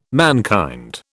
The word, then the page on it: mankind: British English